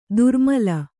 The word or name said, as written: ದುರ್ಮಿಲ